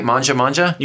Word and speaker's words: Mangia Mangia: Did I say it right? Manja Manja? – You did.